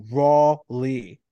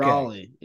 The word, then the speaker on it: Raleigh: It's really it's pronounced Raw R-A-W-Lee. Raleigh. Okay. – Raleigh.